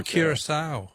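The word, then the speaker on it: Curaçao: I don't know if I pronounced it right, but I thought it was Curacao. – Oh, Curacao, yeah. – Yeah. – No, that's very good. I'm sure you're pronouncing it correctly, but that's not...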